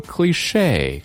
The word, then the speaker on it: cliché: 所以 Phoebe 来了一句 A stripper at a bachelor party, that is so cliche. 看这个词，它读作 cliche, 很洋气的一个词儿。